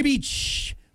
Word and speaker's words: Bitche: The place is called Villa de Beach. Villa de Beach. – Villa de Beach. – Well, it's spelled B-I-T-C-H-E, but it's pronounced like beach, which is bad that they shut this down because it had critical information about COVID screenings and school closures.